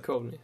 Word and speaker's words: Duchovny: Hur uttalar man det? Duchovny. Duchovny. – Duchovny hade jag nu sagt. – Duchovny, ja.